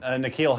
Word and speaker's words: N'Keal: Hakeel Harry? – Oh, N'Keal Harry. – N'Keal? – Yeah, I'm sorry, N'Keal. I'm sorry, N'Keal. – Yeah. – N'Keal in this case is spelled N apostrophe K-E-A-L. N'Keal.